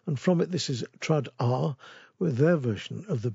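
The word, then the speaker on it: Trad Arr: Trad R, as in traditional arranged by. Trad R, a better spell it, is T-R-A-D-A-R-R-R. Yes, three R's.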